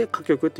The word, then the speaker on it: 火局: これで歌曲って読むんですが旧歯科性の働きを持つ方位のことを歌曲って呼ぶんですね。